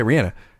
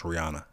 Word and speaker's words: Rihanna: Need, as I say, Rihanna. That's how white people say her name. Rihanna. No, we all know it's pronounced Rihanna.